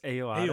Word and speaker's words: Ayoade: Richard, how do you say Richard Oyoade? Oyoade? – I think it's Ayoade.